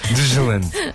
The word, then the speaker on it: Deutschland: Deutschland.